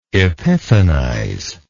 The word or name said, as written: epiphanize